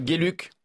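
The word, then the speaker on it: Geluck: Je dis bien Gueluc et pas Gueluc.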